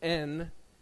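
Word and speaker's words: en (ἐν): And this compound Greek word is actually made up of the Greek preposition in, which is epsilon and nun. It's translated in, okay, and it's pronounced en, and maybe my language is limited to communicate that.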